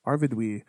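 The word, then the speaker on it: Arvedui: But the king of the last king of Arthedain, his name is Arvidui, right? Is that how you'd say that? – That's how I'd pronounce it. – Arvidui.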